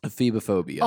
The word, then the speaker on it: Ephebiphobia: Ephebophobia. Ephebophobia. That's spelled E-P-H-E-B-I phobia. Is the fear of what? – Wait, say that again. E-P-H. – E-B-I phobia. – Ephebiphobia. – Ephebiphobia. – Okay. – Or ephebophobia.